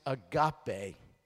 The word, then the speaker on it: agape: It looks like agape, doesn't it? But it's actually in the Greek pronounced agape.